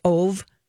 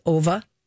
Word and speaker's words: Ove: And I went, wait a second, is this based on the book that I just finished called A Man Called, some people might think it's pronounced Ove, but it's Ova. – Okay. – A Man Called Ova, and because it's spelled O-V-E.